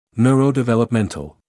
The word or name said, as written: neurodevelopmental